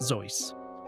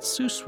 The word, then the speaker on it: Seuss: Jennifer asked me to mention here that Theodore Giesel's pen name was based on his mother's maiden name, which was spelled like Seuss but actually pronounced Zeuss. At first, Giesel tried to gently correct mispronouncers, but Seuss was so widespread that he gave up.